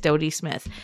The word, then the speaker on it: Dodie Smith: Yeah, it is Dodie Smith.